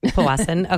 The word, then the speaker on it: Powassan: Yes, and you're saying it right. – Powassan.